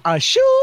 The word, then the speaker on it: Ashoo: So make sure to check him out, along with Nick, what is it, Ashoo? Is that how you say it? – Yeah, yeah. Like the sneeze.